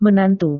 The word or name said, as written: menantu